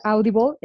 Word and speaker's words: Audible: está Audible, que se pronuncia Audible, esa es de Amazon, y todos los contenidos para niños están gratuitos ahorita.